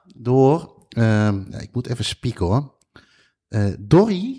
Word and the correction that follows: Dorji: zeg ik dat goed? Dorji. Dorji. Ja.